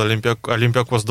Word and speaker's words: Олимпиакос: Скажи, пожалуйста, ударение куда правильно ставить? – Олимпиакос, я думаю так. Хотя я не в греческом не силен.